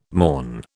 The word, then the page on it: morn: British English